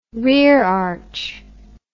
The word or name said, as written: rerearch